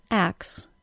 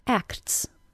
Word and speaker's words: acts: When I say the word axe, I don't actually say the T sound.